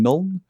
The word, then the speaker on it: Nuln: We also looked at the sacking of the Moot, the ruin of Talibheim and Nuln. Did I pronounce that right? – Yeah. – The ruin of Talibheim and Nuln.